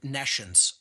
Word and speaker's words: nescience: most people never use or hear the word nescience. So I'll even spell it for the listening audience. It's N-E-S-C-I-E-N-C-E, like N-E and then the word science, meaning no science.